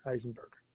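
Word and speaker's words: Isenberg: It's I-S-E-N-B-E-R-G, Eisenberg.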